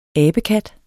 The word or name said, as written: ABEKATTES